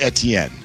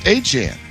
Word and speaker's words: Etienne: Because he says it's not pronounced Etienne. He says it's pronounced Achan.